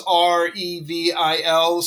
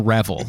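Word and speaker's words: REvil: So, and even then, there was some debate because it's R-E-V-I-L. So some people pronounced it R-Evil. – Yep. – I always called it Reval. And I know they preferred R-Evil, but they're the bad guys, so they don't get to decide how I'm going to pronounce their name.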